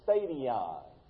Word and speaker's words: stadion: Sometimes we pronounce that as stadion. Sounds like the word stadium, doesn't it?